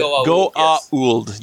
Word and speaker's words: Goa'uld: So the Goa'uld, is that how you say it? – Goa'uld.